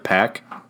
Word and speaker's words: Paak: Why is it Anderson period P P-A-A-K, and they want me to pronounce it Pack. – P-A-A-K?